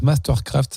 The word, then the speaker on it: MSTRKRFT: C'était alors un groupe, ça s'écrit MSTRKRFT, en fait ça se prononce Mastercraft,